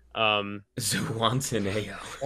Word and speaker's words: Zihuatanejo: Zihuatanejo. – That's how you pronounce it, but thank you.